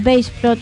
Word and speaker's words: Baceprot: Voice of Bassprot, pronunciado sería Bassprot, a menudo abreviado BOB, es un trío de rock femenino de Indonesia formado en Garut, Java Occidental, en el 2014.